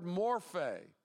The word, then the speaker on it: morphe: The word here for very nature is the word morphe, M-O-R-P-H long E.